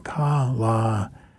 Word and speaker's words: Kalaniemi: I'm always saying that wrong. Kalaniemi. Okay, and she did a polka there, at least a Finnish interpretation of a polka called the Limpko Polish.